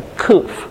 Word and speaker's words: Kuf: And Kuf says, it's transliterated sometimes as Q, but without a U, we wouldn't have that sound. So it sounds like a K. Sounds like a K again. So Kuf.